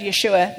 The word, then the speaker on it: Yeshua: What intolerance for a religion to accept Yeshua, that's the Hebrew pronunciation of Jesus' name, to accept Yeshua's claim to be the only way to God.